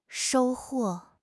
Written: shou1 huo4

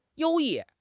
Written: 优异

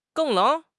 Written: geng4

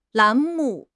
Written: 栏目

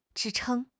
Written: 支撑

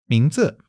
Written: 名字

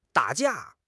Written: 打架

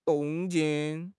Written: 动静

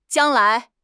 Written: jiang1 lai2

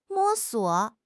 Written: 摸索

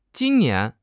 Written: jin1 nian2

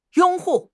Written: 拥护

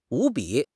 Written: 无比

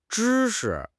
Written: zhi1 shi5

